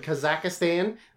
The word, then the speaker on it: Kazakhstan: 29-year-old Bolton has been wrestling in his home country of Kazakhstan. I'm going to call it that because that's how it's pronounced.